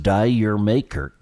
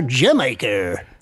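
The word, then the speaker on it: D'yer Mak'er: And we also know it's not pronounced Die Your Maker, is it? – It's no longer Die Your Maker. It is now Jamaica.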